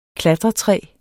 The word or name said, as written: klatretræ